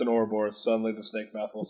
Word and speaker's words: Ouroboros: I think it's pronounced Ouroboros. – It's pronounced Ouroboros.